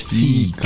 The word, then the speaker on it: Fika: Fika Fika oder so, ne? Fika, wichtig, ja. die Betonung ist ja. anders. Fika. Fika.